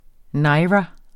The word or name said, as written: naira